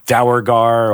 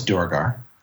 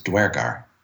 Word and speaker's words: Duergar: I think I said Dowergar or so I'm some other messed up pronunciation. – It has two canonical pronunciations. Unusual. – What is that? – One is Duergar. The other is Duergar.